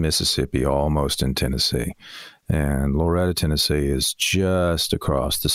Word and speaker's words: Loretto: You are not. You're saying it properly. You're saying it the way it probably should be pronounced. But I know immediately you're not from there. anybody there would pronounce it Loretta, like Loretta Lynn. It's yeah. Loretta, Tennessee. And it's funny to hear anybody else pronounce it.